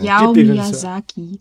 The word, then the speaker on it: Hayao Miyazaki: Hayao Miyazaki.